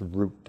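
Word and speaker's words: Wroot: The most important one was Epworth, and he also had a neighboring church called Root, that is pronounced Root, W-R-O-O-T-E.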